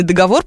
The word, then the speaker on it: договор: И если у вас есть проблемы с тем, как склонить этот несчастный договор, пожалуйста, вот он, помидор, точно так же ставишь ударение.